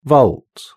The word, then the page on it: vault: British English